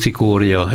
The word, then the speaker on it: Cikória: Egyik kollégám szerint Cikória, ez a helyes kiejtés.